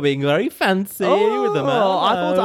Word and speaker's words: Merlot: How would you pronounce a famous red wine? – Merlot. – Incorrect. – Oh! – Yes. Actually, it's Merlot.